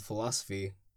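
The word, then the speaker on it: philosophe: So, on to our next article, which is called Philosophy, spelled with an E at the end. – What? – It's philosoph. – It's philosoph.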